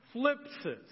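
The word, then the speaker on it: phlipsis (θλῖψις): You don't pronounce the TH, so let's say that out loud, phlipsis.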